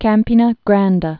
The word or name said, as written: Campina Grande